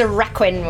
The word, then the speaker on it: Raquin: Therese Raquin. – Yeah, that one. It's the surname. How do you do the surname? – Theresa Raquin, we're saying. Yeah. Loads of the Therese Raquin.